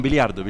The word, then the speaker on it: biliardo: Billardo, sí. No, biliardo, sí, perdón.